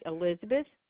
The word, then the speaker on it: Elizabeth: Sorry, Elizabeth.